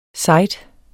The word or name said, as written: site